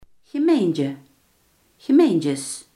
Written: gemeente

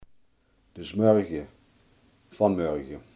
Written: vanmorgen

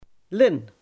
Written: Linne

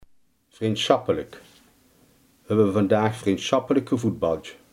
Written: vriendschappelijk

